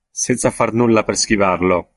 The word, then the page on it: nulla: italyanca